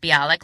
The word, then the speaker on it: Bialik: I'm assuming it's pronounced Bialik, like a Bialy with a K at the end, the New York onion bagel type thing for people who don't know.